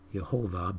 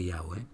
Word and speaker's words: Yahweh: Some pronounce it Jehovah, but it's more accurately Yahweh.